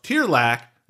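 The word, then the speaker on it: Tearlach: And so on this episode of Gaelic 101, we can all learn how to pronounce Tierlac, which is actually like Charlie or Sherlock.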